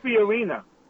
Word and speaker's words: Fiorina: He did not say that. He said Fiorini. Or something. – I said Fiorina.